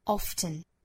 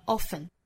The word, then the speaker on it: often: 那我们来看啊，首先要注意一个单词的发音，就是 O-F-T-E-N, often 和 often 这个词其实是有两种发法的。我们在日常生活之中可能听到老外把这个词读成 often, 或者像美音的 often, 那也就是相当于把这个 t 的音发出来了。